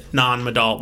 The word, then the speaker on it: Nan Madol: N-A-N, that's one word, and the other word is M-A-D-O-L, Nan Madol. I watched a YouTube video, and a local pronounced it Nan Madol. Well, Nan Madol. – I'm butchering it with an American accent, but, you know.